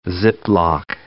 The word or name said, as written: ziplock